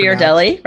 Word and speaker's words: Ghirardelli: okay, when I was in San Francisco in the Gir- I can't hard to Ghirardelli, right? Thank you.